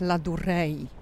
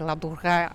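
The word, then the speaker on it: Ladurée: Ja muszę się przyznać, że jak byliśmy w tej cukierni, to sprawdziłam w internecie, jak powinnam wymawiać nazwę, bo ja tak powiedziałam Ladure, nie znam francuskiego, nigdy się nie uczyłam i pierwsze w ogóle co mi się wyświetliło, jakieś Ladurei ale to chyba taka angielska wymowa, bo potem dokopałam się do pana, który mówił po francusku i to było takie la durée, ale ja oczywiście nie jestem w stanie tego powtórzyć z odpowiednim akcentem, ale to la durée to chyba będzie dobrze.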